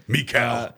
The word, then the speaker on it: Mikal: The Lakers receive Kyrie Irving and Landry Shamet, and then the Nets would receive Westbrook, Ayton, Michael Bridges. I don't know if I said his name right. – Michael Bridges, whatever. – Mikal.